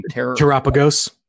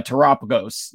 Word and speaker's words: Terapagos: You know, basically, people were like, "Well, is this I'm going to butcher the name. – Terapagos. – Yeah, Terapagos. That's yes."